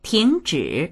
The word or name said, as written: ting2zhi3